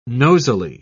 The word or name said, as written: nosily